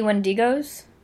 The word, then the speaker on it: Wendigo: Shitty Wendigos.